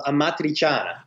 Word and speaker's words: amatriciana: How do you pronounce it, first of all? – Yes, I actually already said it. It's called amatriciana. – Amatriciana. Very good.